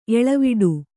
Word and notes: ಎಲೆವಡೆ: Kannada

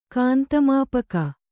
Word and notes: ಕಾಂತಮಾಪಕ: kāntamāpaka

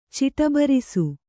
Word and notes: ಚಿಟಬರಿಸು: Kannada

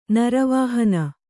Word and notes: ನರವಾಹನ: naravāhana

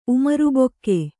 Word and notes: ಉಮರುಬೊಕ್ಕೆ: Kannada

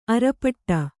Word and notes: ಆರ್ಪೊಡೆ: ārpoḍe

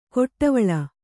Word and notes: ಕೊಟ್ಟವಳ: Kannada

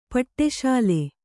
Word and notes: ಪಟಸಾಲೆ: Kannada

